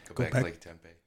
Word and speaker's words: Göbekli Tepe: Göbekli Tepe.